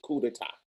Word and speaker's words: coup d'état: Coup d'état is a French word and I'm saying coup is spelled C O U P, but the French word, the total saying is coup d'etat.